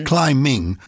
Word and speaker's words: climbing: When you say, as casually as though we all knew this, we used to pronounce the B in those days, climbing, you would think it's spelled C-L-Y-M-I-N-G, but it's actually spelled C-L-I-M-B-I-N-G.